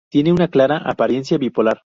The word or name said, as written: clara